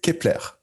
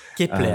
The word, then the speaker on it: Kepler: je vais juste corriger la prononciation. C'est Kepler. – Kepler, euh, d'accord.